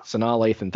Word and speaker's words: Sanaa Lathan: Yeah, and then for the love of basketball, you had a very young Omar Epps and a very young Sanaa Lathan. – Sanaa Lathan. – Thank you for the proper enunciation of her name.